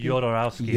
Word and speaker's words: Jodorowsky: Jodorowsky? – Jodorowsky. – Jodorowsky. – Alejandro Jodorowsky. – Jodorowsky. And this is the movie that you guys assigned to me. – Yes. – For my Jodorowsky blind spot, because I hadn't seen any of his movies.